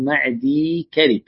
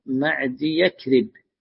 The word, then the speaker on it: معدي كرب: في هذا الحديث يقول اسم الراوي أو الصحابي: المقدام بن معدي كرب، هكذا نطقه الصحيح، لأن بعضهم يقرأها معدي يكرب، والصحيح كما نطقتها. وسبب أن بعضهم يقرأها معدي يكرب أن الياء أحيانا تكتب متصلة بكلمة كرب، فبسبب هذا التوصيل أو الوصل صار ينطقها بعضهم خطأ.